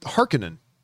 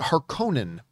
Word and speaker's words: Harkonnen: Well, then I'm watching the movie, and sure enough, they pronounce it Harkonnen. And I've always pronounced it Harkonnen, but I was wrong.